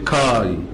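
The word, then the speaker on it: كاي (καί): ففي مثلا كلمه مشهوره في العهد الجديد كاتا وبعديها الفا يوتا تتنطق كاي اللي هي الاند بالانجليزي حرف العطف يعني. فدي بتتنطق كلها على بعض كاي.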